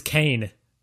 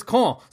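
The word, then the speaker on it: Caen: But the actual pronunciation is Con.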